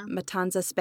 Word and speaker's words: Matanzas: It sits along the Matanzas Bay. I hope I'm saying that right. – Yep, you're saying it right.